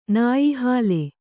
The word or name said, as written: ನಾಯಿಹಾಲೆ